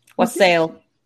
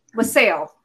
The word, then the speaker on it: wassail: There is beverage, which it stems around the, I'm going to say this wrong, wassailing? – Wassail. – Wassail.